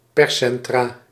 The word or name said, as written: perscentra